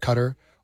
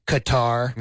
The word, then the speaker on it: Qatar: They also have some joint natural gas fields with Qatar, or as Americans say, Qatar.